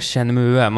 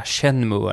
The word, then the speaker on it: Shenmue: viktigt att man uttalar det Shenmue. Man ska inte säga Shenmue eller något sånt skit.